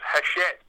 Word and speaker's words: Hachette: I noted that Hatchet Partwork have looked at doing. – Yeah, well, it's actually pronounced Hachette, but I love the way you pronounce it, Hatchet.